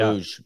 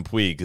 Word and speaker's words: Puig: Yeah, I don't know how we get to that from Puig is what I was ready to say there, but apparently that's how you say it, which has been news to me this week.